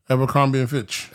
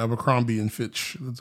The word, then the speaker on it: Abercrombie and Fitch: Abercrombie and Fitch. – Abercrombie and Finch? – Yeah, Abercrombie and Fitch.